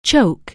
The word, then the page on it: choke: anglais